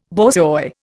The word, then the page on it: borzoi: Indian